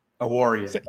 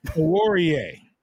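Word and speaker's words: Awuzie: So, but, and we, I can't pronounce his name. Amani. Oh. – Awarie. – You say it better. Yeah, you say it better. Say it again. – Awarie, I believe. – Awarie.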